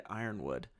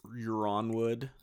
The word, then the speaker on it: Ironwood: Ironwood. Am I the only idiot that pronounced it Euronwood for the first like, the first time? – I totally did.